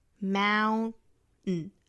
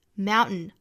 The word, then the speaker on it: mountain: so I can, so 和 I 连读加 w, so I can stand on mountains, stand 和 on 连读成 stand on, 这个 mountain 里面是一个 ow 的双元音，同样美音的发法呢嘴巴向下压啊，mountain 近似于一个梅花 a 的发音，mountain mountain 或者是把 t 发出来也可以，mountains mountains 都行。I can stand on mountains You raise me up to walk on stormy seas.